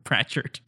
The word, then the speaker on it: Pratchett: I am looking for the best book ever written by Terry Pratchett.